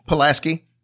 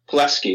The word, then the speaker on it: Pulaski: This is in Kentucky, but it's actually in Pulaski County? – Pulaski. – Pulaski is how you say it.